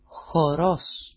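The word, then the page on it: χορός: xoro-s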